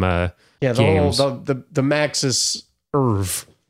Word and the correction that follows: oeuvre: Yeah, did I pronounce that word? – Oeuvre. The Maxis Oeuvre.